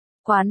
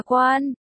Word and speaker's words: quán: Quán là cái mũ, còn đọc là quan,